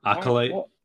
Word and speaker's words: Acolyte: We got Star Wars Acolyte. Is that how you say it? – Acolyte. – Acolyte, that's it.